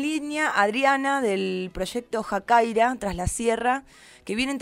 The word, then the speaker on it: Jakairá: Bueno, mira, nosotros somos el programa Jacaira Tras la Sierra, así con acento en la A.